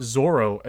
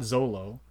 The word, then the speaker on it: Zoro: Also, also this Japanese actor Mackenyu, however you want to pronounce it, they put Zoro as the name, not Zolo. – Let's so, go. I hate hearing Zolo.